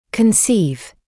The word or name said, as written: conceive